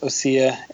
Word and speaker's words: ossia: My handle is OSSIA, which is pronounced OSIA. It means like an alternate passage of music.